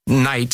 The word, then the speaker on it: Knight: Night spelled with a K, like knight in shining armor.